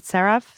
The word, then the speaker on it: CeraVe: And another cream that was recommended to me the other day was this, and I don't know how to pronounce this brand. Is it CeraVe? – Oh, yes.